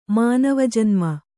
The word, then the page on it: ಮಾನವಜನ್ಮ: kannada